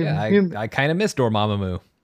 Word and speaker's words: Dormammu: And I think his relationship with Dormammu, you're proud of me, I said it correctly. – I prefer Dormammu. I don't think there's ever anything wrong with you saying that.